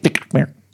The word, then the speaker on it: NGHTMRE: N-G-H-T-M-R-E. – N-G-H-T. – So they took out the I in night and the A in mare. – So there are no vowels? – It's pronounced DJ Nightmare.